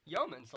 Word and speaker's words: Yeomans: Yeomans, like Yeoman. Man.